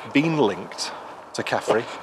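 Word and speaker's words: Khafre: It's K-H-A-F-R-E. – Caffrey. – Yes. – Yeah. – Caffrey. It's the Irish beer from the 1990s.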